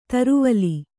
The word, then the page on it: ತ್ರಿವಲಿ: kannada